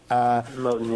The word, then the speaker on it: Moten: But I am bewildered by Afro-pessimism and, by the way, by a couple of the other intellectuals, Monet, if I'm pronouncing that right. – Moten, yeah.